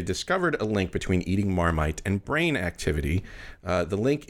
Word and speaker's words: Marmite: It's actually named after the container it once came in, which is a Marmite, I guess you might say in French. – Yeah, I couldn't tell by your earlier pronunciation. – Marmite. Yeah. – Ouch. – Sorry. Well, doesn't speak French. It's okay. Don't feel bad. – Yeah, Marmite. – That was much better.